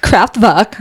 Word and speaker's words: Kraftwerk: It's pronounced Kraftwerk.